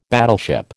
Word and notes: battleship: American English

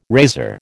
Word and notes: razor: American English

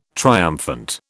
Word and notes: triumphant: British English

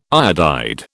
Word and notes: iodide: British English

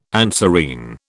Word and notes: anserine: British English

